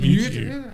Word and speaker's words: Mewtwo: No, I think it was a Mewtwo. Is that how you say it? – Mewtwo.